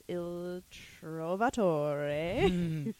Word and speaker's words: Il Trovatore: The Met Opera, which is Verdi's Il Trovatore. For sure, that's how you pronounce it. – Yeah, 100%. Definitely.